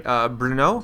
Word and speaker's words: Brno: Brno? – Mm-hmm, yeah.